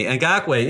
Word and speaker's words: Ngakoue: Ngakoue, yeah.